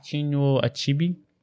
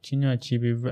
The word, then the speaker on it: Chinua Achebe: Chinua Achebe. – You should know. – Yeah, I should know. Chinua Achebe, amazing guy, amazing guy.